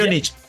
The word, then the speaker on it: Múnich: y Bayern Munich. Múnich.